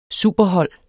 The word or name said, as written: superhold